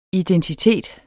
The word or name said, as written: identitet